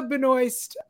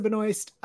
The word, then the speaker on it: Benoist: The chat is already going crazy discussing Melissa Benoist, who is from Supergirl.